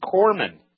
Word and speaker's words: corpsman: that it's pronounced corpsman.